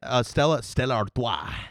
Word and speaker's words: Stella Artois: We're talking about, how do you say it? Stella Artois. – Yeah, exactly.